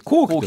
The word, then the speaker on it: 聖: 後期